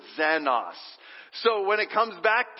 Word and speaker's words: xenos: The second love is the word xenos. Let's say that together. Ready? 1, 2, 3. Xenos. Now it's zen, but it's actually spelled with the letter that we use for X.